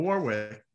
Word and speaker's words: Warwick: He's like, no, not the first W. – And actually, that's maybe my problem. I didn't know there was no Warwick.